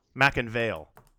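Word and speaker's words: McIngvale: We have Mattress Mack, Jim McElvain. Am I saying that right? – Mack and Vale.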